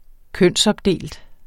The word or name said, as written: kønsopdelt